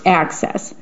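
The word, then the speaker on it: AHCCCS: Arizona's $23 billion Medicaid program is called the Arizona Healthcare Cost Containment System, or ACCESS, pronounced ACCESS.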